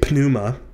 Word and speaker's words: pneuma: In English, when we have a P before an N, the P is silent. But in Greek, you would say pneuma.